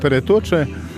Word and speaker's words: Peretocze: Bo Peretocze, to właściwa nazwa, trzeba je wymawiać z dyftongiem Peretocze, to miejsce piratoka, matok, to po polsku klepisko.